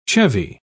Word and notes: chevy: British English

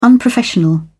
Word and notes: unprofessional: British English

